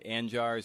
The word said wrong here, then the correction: Arjan: Yeah, this guy named Arjan, I think Arjan. Is how you pronounce it, is from the Netherlands.